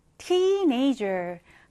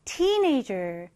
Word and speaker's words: teenager: teenager. 강세를 앞에 강하게 주시면 되겠습니다. teenager.